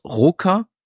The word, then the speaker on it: ROKR: Diesmal nicht von Yu-Gi-Oh!, sondern die Firma heißt, wie spricht man es aus? Roker, R-O-K-R.